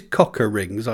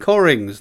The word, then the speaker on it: Cockerings: C-O-C-K-E-R-I-N-G-S, which is cocker rings, I know, but it's pronounced Corrings.